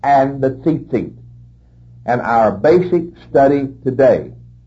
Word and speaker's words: tzitzit: You spell that T-A-L-I-T, talit. And the fringes on the border of that garment are called tzitzit, T-Z-I-T-Z-I-T, tzitzit.